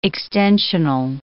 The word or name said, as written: extensional